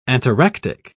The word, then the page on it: ataractic: British English